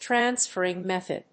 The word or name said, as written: transferring method